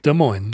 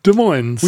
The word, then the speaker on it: Des Moines: Weirdly, we have a town here nearby called Des Moines, where we don't pronounce the S on the days, but we do pronounce the S at the end. – In Iowa, they don't pronounce either S.